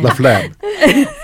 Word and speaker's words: Laflamme: No, you didn't. – I said LaFlemme. – And I was like, damn it. It's not, you're saying it like it's phlegm. It's LaFlemme. – LaFlemme.